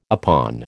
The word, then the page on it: upon: American English